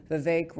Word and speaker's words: Vivek: And Vivek, by the way, Ramaswamy, he said in one debate, my name is Vivek rhymes with cake. So that's how you say it, Vivek Ramaswamy.